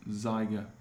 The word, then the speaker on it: Zeiger: And I've been told on good authority that Zeiger is not spelled the same, but it's pronounced like Tiger with a Z. So if you're ever unsure about how to pronounce his last name, it is Zeiger.